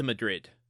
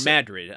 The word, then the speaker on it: Madrid: I think when they get to Madrid. – Okay, well, Madrid. I'm sorry, I got to pronounce it correctly.